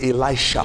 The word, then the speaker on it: Elisha: And Elisha sent a messenger unto him, saying, actually, it's Elisha. – Elisha, yes, Pastor. – Because it's pronounced the same way. Elijah, Elisha. Yes, it's the same thing. Yes. Not Elise.